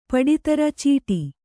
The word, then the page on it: ಪಡಿತರ ಚೀಟಿ: paḍi tara cīṭi; Kannada